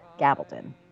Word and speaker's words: Gabaldon: Gabaldon.